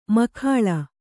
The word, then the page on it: ಮೇಖಳೆ: kannada